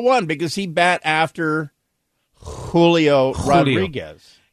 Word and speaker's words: Julio Rodriguez: This Julio Rodriguez.